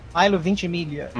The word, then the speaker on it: Ventimiglia: A série realmente se chama Chosen, com Milo Vermilha. Com quem? Milo Vermilha. Milo Ventimiglia.